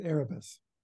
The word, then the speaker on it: Erebus: or do you call it Erebus? Is it Erebus? Which one is it? – I pronounce it Erebus.